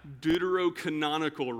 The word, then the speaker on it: Deuterocanonical: Deuterocanonical writings.